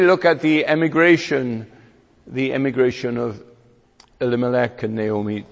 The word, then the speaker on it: Elimelech: I was waiting to see what our chairman was going to, how he was going to pronounce it, because for years I always pronounce it in the Welsh way, Elimelech, I pronounce it, and now I've got to, in America, Elimelech. So it's going to be Elimelech, all right.